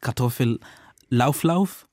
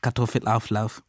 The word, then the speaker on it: Kartoffelauflauf: Wir haben über deutsches Essen gelernt und unser Lektor hat immer dieses Wort richtig gesagt. Also nicht Kartoffellauflauf, aber Kartoffelauflauf.